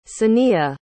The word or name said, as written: saniya